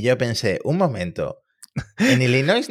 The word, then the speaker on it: Illinois: porque Illinois, eh, o Illinois, como lo pronuncian allí, creo que la S la, cortan un poco, la pronunciación local, Illinois, había abierto una investigación, no recuerdo ahora mismo, ¿no?